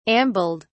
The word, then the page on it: ambled: British English